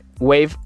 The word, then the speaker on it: Wavve: Wave se escribe W-A-V-V-E. Wave, con, o sea, como hola en inglés, pero con W.